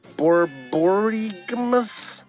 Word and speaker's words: borborygmus: And man, I wish I had talked to Brenda before reading this first word, but it's borborygmus. – That's right. That is correct. Yeah, I know that one. That's 100% correct on pronunciation.